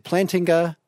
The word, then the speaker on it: Plantinga: The way, is it Plantinga? Is that how to pronounce his name? Oh goodness! It is tonight. Rob, what do you think? – Yeah, there you go. yeah, that's the way.